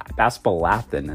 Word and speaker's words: aspalathin: And like catappa leaves and other botanicals, it contains polyphenols like flavones, flavonols, aspalanthin. But boy, did I butcher that name. Aspal, aspalathin is what it's called. I call it aspalanthin.